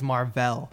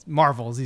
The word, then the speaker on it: Mar-Vell: And she's like, it's Mar-Vell. And Nick Fury's like, Marvel's easier to say. And, like, that's the extent of the name origin. So I don't even know. – So we've been saying it wrong all these years is what everyone's telling me exactly. – Right now. It's Mar-Vell, all these Mar-Vell classics.